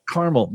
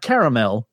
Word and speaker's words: Carmel: It is Indiana, so therefore it is pronounced correctly. It is Carmel. – Carmel, not caramel. Carmel.